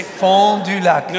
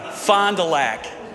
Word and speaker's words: Fond du Lac: Can you try to pronounce it the right way? Can you say? – Fond du Lac. – No. Fond du Lac.